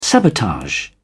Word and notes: sabotage: British English

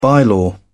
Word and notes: bylaw: British English

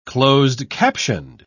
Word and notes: closed-captioned: British English

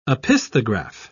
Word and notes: opisthograph: British English

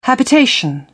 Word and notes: habitation: British English

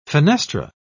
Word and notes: fenestra: British English

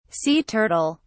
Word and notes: sea turtle: British English

